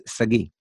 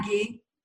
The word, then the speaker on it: Sagi: It's Sagi. – Okay. – It's pretty much how it's written. Sagi. – Yeah. Mm. Yeah, it's like Sagi.